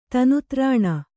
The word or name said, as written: ತನುತ್ರಾಣ